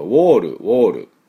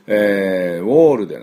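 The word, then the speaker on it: wall: 日本人はどうしてもこれ発音するとウォールというふうに言ってしまいますが、ウォールではなくてウォールなんですね。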